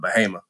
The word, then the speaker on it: Bahama: It's spelled like Bahama, but you know they country down there they call it Bahama.